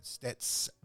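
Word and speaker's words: Stetsasonic: I just learned yesterday that Legend Daddy-O from Stetasonic. – Stetasonic. – Did I say that wrong? – Yeah, I don't know how the hell you said it. – But I said Steta. Right. – Yeah, Stets.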